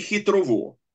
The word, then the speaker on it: Хитрово: Мертваго, с ударением на предпоследний слог, и две с ударением на последний слог. Турново и хитрово.